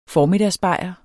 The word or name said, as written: formiddagsbajer